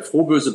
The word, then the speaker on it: Froböse: Und das bedeutet, dass wir beide einfach äh, bei dem Frohböse bleiben. Und die Betonung, das ist mir ganz wichtig natürlich, auf dem Froh und nicht auf dem Bösen.